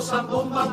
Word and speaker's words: zambomba: Lo cierto es que Zambomba con Tirdela es palabra queda. Y sin embargo Zambomba, palabra llana sin tilde alguna.